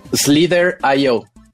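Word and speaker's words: Slither.io: Slither I.O. Lo pronuncian I.O., pueden decirle I.O. Slither I.O., que fue de los primeros que empezó a tener éxito con multiplayer en línea masivo.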